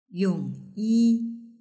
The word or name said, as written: yongyi